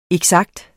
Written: eksakt

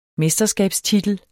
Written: mesterskabstitel

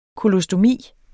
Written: kolostomi

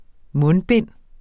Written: mundbind

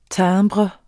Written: timbre

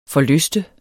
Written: forlyste

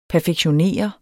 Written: perfektionere

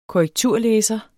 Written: korrekturlæser

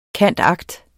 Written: cand.act.

